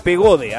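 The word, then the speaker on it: Pagode: La compañía de Pegado. Pegado. Pegade. Pegado de. No sé cómo se pronuncia. Pegode, ahí está, muy bien.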